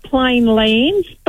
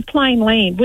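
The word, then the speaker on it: Plainland: I always call it Plain Land, but it's actually Plain Land, which doesn't sound right. – There's no S.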